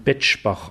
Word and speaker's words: Bexbach: Wir haben ja auch einen interessanten Ort im Saarland, Bexbach, der dann Betschbach ausgesprochen wird.